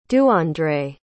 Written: duandré

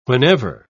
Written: whenever

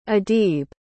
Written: adib

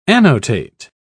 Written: annotate

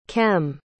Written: kem

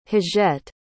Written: hugette

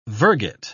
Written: virgate

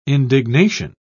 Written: indignation

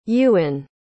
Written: Ewan

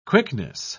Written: quickness

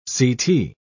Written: ct

